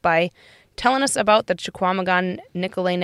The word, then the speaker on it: Chequamegon: So most everything that I've been coming across is Chequamegon. – Whoa, I was way off. – No, you weren't too bad. You just pronounced the Q. – Chequamegon.